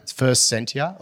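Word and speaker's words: First Sentier: So First Sentia, is it that how you pronounce it? – First Sentia.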